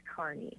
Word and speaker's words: Kearney: And we actually have a caller here, from Keenery, I believe. Welcome to Central Standard. – Thank you. – Did I get that town correct? Where are you calling from? – Well, locally it's pronounced Kearney.